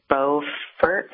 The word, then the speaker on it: Beaufort: In North Carolina, we pronounce it Beaufort, almost as if it was spelled F-E-R-T, and we leave Beaufort to South Carolina. It's a little confusing. Two states, same spelling.